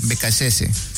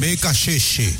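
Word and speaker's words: Beccacece: ¿Cómo dice la pronunciación? – Becachese. – Sí, Becachese. – Ahí ya tenemos el listado de los convocados. – Así, Becachese. Bueno, esa es la correcta pronunciación.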